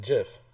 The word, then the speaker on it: GIF: What's the right way to say it? – Jif.